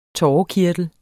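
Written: TÅREKIRTEL

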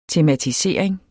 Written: tematisering

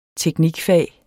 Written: teknikfag

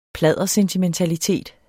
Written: pladdersentimentalitet

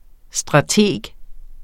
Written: strateg

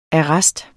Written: arrest

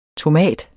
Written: tomat

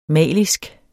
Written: malisk

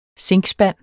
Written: zinkspand

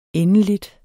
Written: endeligt

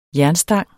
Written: jernstang

